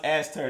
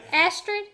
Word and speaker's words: Astrid: Is when Jan had the baby and they had the name and it was like it's Aster, but it wasn't pronounced Aster. It was like Astrid. – Astrid. Thank you.